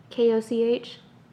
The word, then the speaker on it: Koch: Koch, uh, Koch, Koch, Koch, Koch, Koch. Spell it. K O C H.